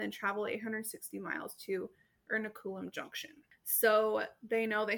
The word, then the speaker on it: Ernakulam: Is that how you pronounced it? Ernakulam. Yeah. – Yeah, that's a better way of pronouncing it than I ever could.